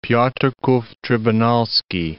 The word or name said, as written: Piotrkow Trybunalski